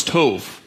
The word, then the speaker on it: tov: They say it wrong. It's tov. Tov.